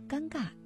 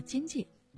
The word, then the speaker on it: 尴尬: 比如吴克群，他的这首《先生你哪位》当中就把尴尬念成了尖界。